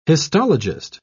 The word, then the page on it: histologist: British English